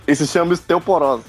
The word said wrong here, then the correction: osteoporose: Falei errado? Fala errado. – Osteoporose.